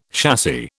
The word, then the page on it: chassis: British English